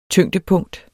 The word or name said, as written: tyngdepunkt